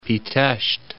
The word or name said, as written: Pitesti